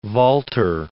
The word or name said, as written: Walter